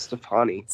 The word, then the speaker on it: Stefani: Is it Stephanie or Stefani? – I feel like it's Stefani. – Stefani. It's spelled funny.